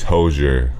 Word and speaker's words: Hozier: And I found him too, like I like Hosier. I keep saying his name wrong. It's Hozier.